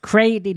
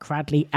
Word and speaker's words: Cradley: Can I just point out that it is pronounced Cradley, not Cradley, as per the spelling.